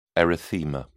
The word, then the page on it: erythema: British English